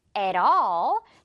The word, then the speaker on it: at all: 지금 중간에 T 있고요. 양옆에 모음 소리 있습니다. 그래서, at all. at all. 이렇게 시냇물 흘러야겠죠? 리을 소리 난다는 거.